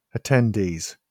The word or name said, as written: attendees